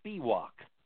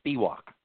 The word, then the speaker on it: Spiwak: And her last name's Spiwak, S-P-I-W-A-K, Spiwak. – One more time on that. One more time on that last name. – Spiwak. Spiwak is the way it's pronounced.